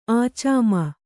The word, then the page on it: ಆಚಾಮ: ācāma